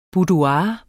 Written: BOUDOIRETS

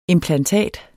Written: implantat

